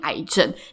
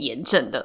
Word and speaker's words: 癌症: 但因为后面有一些就是可能发炎的词汇在里面，所以我还是决定念大家比较通用的读音癌症。但是我知道这个字呢，目前在教育部是念炎症的。